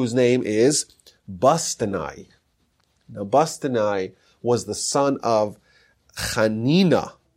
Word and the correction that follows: Haninai: uh, Haninai actually, pronounced Haninai.